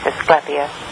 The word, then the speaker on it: Asclepius: I think it's Asclepius.